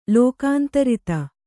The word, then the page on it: ಲೋಕಾಂತರಿತ: Kannada